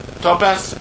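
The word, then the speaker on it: Tophet: And he defiled Topitha. Did I say that right? Topitha? Topeth,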